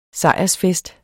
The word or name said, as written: sejrsfest